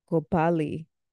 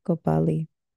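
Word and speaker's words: copalli: Which copal comes from the word, Nahuatl word, copali. And so that has been a staple in the Aztecas, the Maya people as well. They're all together. But that word comes from copali. So say it right, put some motherfucking respect on it.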